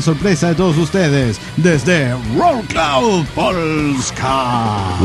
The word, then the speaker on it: Wrocław: Dice, hola amigos de la nave, les escribo desde la hermosa y siempre lluviosa ciudad de Wrocław, que por alguna razón se pronuncia Wrocław.